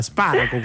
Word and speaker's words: Sparaco: Il nome corretto, la pronuncia corretta del nome è Simona Sparaco o Simona Sparaco? – La prima che hai detto. – Quindi, buonasera. Buonasera Simona, Sparaco quindi.